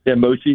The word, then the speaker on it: mochi: But they're like mochi.